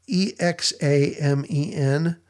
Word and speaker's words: examen: It's spelled E X A M E N, um, which sounds a little bit like examine, which the it's it's kind of the, a lot the same idea, but it's it's pronounced with kind of a long a sound, so examen.